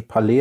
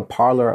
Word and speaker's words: Parler: Also ich habe gerade gelesen von einer Wiener IT-Spezialistin, die mitgekriegt hat am Wochenende, kurz bevor dieses rechtsradikalen soziale Netzwerk Parler, wie es wohl richtig ausgesprochen wird, Parler geschrieben, wie so das französische Parler, aber Parler ausgesprochen.